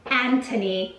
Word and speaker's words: Anthony: His name is Anthony, and was an extremely popular name during the Regency period, and it was pronounced Anthony with a very hard T, which is the pronunciation used in the series.